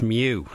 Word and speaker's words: Mu: It's Mu. It's pronounced Mu because it's a Greek letter.